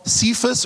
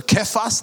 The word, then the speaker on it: Cephas: You will be called Cephas or Kephas, depending on your preferred pronunciation, which when translated is Peter.